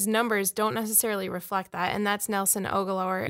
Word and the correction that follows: Aguilar: Do you remember the Twins closer named Rick Aguilera? – Yes. – Go more down that road, Nelson Aguilar.